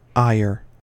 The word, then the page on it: ire: American English